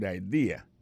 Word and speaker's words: idea: THAT, apostrophe S, good, G-O-O-D pour good, ça veut dire bon, bonne, idea, c'est I-D-E-A, ça veut dire idée, that's a good idea. Alors, accent vient de tomber dans la deuxième syllabe, là, one idea. Alors, moi, c'est idée, vous faites fait trois syllabes vraiment, I-D-A. On dit grand mot comme ça, il y a trois syllabes là, mes amis.